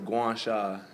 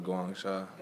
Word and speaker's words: Guangsha: How do you say it? – It was Guangsha. It was a team. I was in the city of Hangzhou, but it was Guangsha.